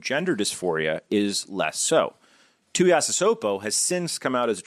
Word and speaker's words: Tuiasosopo: The story of Te'o and his fake girlfriend is well known, but the story of Ronai, how do you say the last name? – Tuiasosopo.